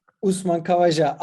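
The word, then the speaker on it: Usman Khawaja: Kavaja diye mi okunuyor? – Evet, Osman Kavaca. – Usman Kavaja.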